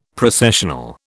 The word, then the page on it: Processional: British English